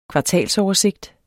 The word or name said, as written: kvartalsoversigt